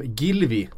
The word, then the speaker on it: Gylfi: Eh, Gylfi Sigurdsson som vi brukar säga då, uttalas inte som byxgylf, utan som Gilvi.